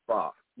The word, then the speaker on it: pho: It's spelled pho, pho, but it's pronounced pho.